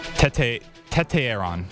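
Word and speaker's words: Tetairoa: How do you say his first name? For some reason, I just have a mind block, and I can't ever say his first name correctly. – Tete Aaron.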